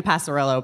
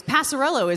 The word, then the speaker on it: Passarello: Passarello isn't a real name either. It's like somebody mispronounced it at Ellis Island. The Italian version is either Passarelli or it's Passerello, like with one of the vowels is changed.